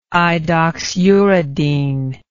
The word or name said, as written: idoxuridine